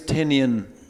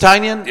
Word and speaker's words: Tynion: We've got James Tynion the IV. – Tynion, but yes. – Tynion? Yeah. Okay, yeah. Sorry. – It's all good, not how it's spelled.